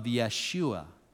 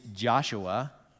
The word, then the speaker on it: Yeshua: There is this dude by the name of Yeshua. The English pronunciation of that word is Joshua, and it is also translated Jesus.